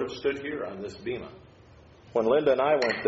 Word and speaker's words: Bema: That's called the Bema, or I understand in Greek it's called Bema. They pronounce the B like a V.